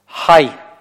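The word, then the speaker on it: Хай (חי): Хай – вот следующее слово начинается с буквы хейт, которая глубокая гортанная.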